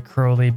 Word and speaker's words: Crowley: I only say Crowley because in his uh, he would often like rhyme his name in his poetry with holy and crowley rhymes with holy but crowley doesn't rhyme with holy